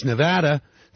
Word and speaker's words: Nevada: To properly pronounce Nevada, the middle syllable should rhyme with gamble.